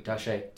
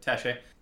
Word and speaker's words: Taché: And then you wrote with Adam Tash. Is that how you pronounce his last name? – Taché. – Taché. It's very French.